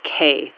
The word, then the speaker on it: Kay: It's Kay Newberry. – Could you spell that for me, please? – Sure. My first name is Kay. That's K-A-Y.